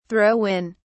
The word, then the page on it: throw in: British English